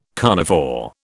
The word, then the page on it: carnivore: British English